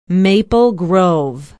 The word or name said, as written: maple grove